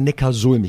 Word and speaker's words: Neckarsulm: Heißt das eigentlich Neckarsulm oder Neckarsulm? Ich glaube Neckarsulm, ne? – Richtig. – Ja, wie siehst du? Ich habe es tatsächlich lange Zeit falsch gesagt, aber Neckarsulm.